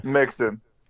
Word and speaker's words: Mixon: His last name rhymes with the president that got... – Mixon.